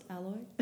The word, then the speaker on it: Alloy: Today in studio we have Dana Ali. – Almost, Alloy. – Oh, Alloy. I can't pronounce her surname because I say it in a South African accent and I just butcher it.